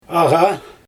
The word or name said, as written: aGa